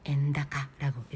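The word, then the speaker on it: 円高: 엔다카, 다카이, 높을 고자를 쓰고 엔다카라고 읽죠.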